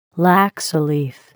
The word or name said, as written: laksa leaf